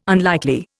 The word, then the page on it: unlikely: Indian